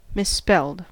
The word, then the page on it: misspelled: American English